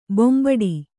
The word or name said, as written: ಬೆಂಬೆಟ್ಟ